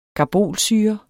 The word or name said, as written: karbolsyre